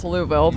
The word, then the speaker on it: Louisville: Well, it's Louisville, but however you're supposed to say it, one less syllable.